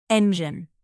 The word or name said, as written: engine